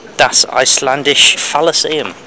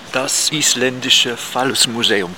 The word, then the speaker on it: Das Isländische Phallusmuseum: You say that I pronounced the Penis Museum wrong. How is it pronounced in Germany? – Das Isländische Phallusmuseum.